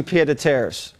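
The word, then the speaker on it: pied-à-terre: Go shop for some pied à terres. I hear there's a few available on the market these days. – Am I even saying that right? I didn't even know that. – It sounds French. It's the only French word I know. – Yeah, that's right.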